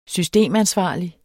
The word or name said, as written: systemansvarlig